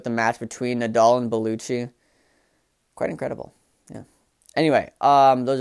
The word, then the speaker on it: Bellucci: Yeah, Bellucci.